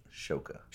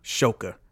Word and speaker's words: Shawkat: So, and it's spelled... – Shawkat. – But it's pronounced Shoka. – Shoka.